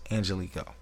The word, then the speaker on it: Angelico: That's how they pronounce it, Angelico.